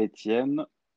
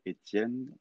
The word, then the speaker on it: Étienne: C'est très difficile pour les Américains de, prononcer Étienne. normalement, ça s'écrit e t i e n n e mm-hmm. Mais j'ai rendu la chose facile en disant c'est comme ATM for cash, but A-T-N.